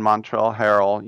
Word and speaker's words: Montrezl Harrell: But if you think about keeping two of the NBA's best players, then you add Dennis Schroeder, you add, I mentioned Montrezl Harrell with the silent Z, you add Wes Matthews, you add the aforementioned Marcus Saul.